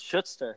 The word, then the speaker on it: Schutzer: And then the federal champion, second time on the podcast, I believe, Mr. Chris Schutzer. Is that right? Second yeah, you're time? Saying it right. It rhymes with put.